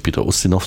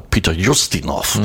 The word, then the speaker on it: Ustinov: Wir sagen ja heute ganz selbstverständlich Peter Ustinov, so würde ich es auch weiter aussprechen, aber da hieß er im Trailer Peter Ustinov.